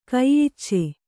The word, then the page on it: ಕೈಯಿಚ್ಚೆ: kaiyicce